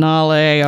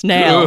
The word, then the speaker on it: Nale: Yes, I've given up on Nale. All right, you good. Win. It's Nail.